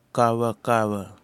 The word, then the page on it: kawakawa: Kwamera